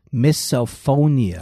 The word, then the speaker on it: misophonia: He has misophonia. – Oh, yeah. Misophonia. – Misophonia. – Or maybe it's misophonia. – Misophonia. – I pronounce it like miso soup.